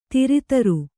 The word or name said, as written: ದರಿದ್ರೆ